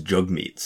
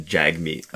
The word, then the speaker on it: Jagmeet: But I know for Jugmeet Singh that it's pronounced more like Jugmeet than Jagmeet.